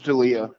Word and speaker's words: D'Elia: It's D'Elia.